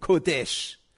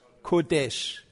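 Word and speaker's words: Kodesh (קודש): Wenitztag, Kodesh. Kodesh. Wenitztag, Kodesh. Übrigens, nicht, dass ihr es dann so schreibt, so würde es keiner schreiben, der Hebräisch kann, aber so schreibt man es, damit man die Aussprache hinkriegt.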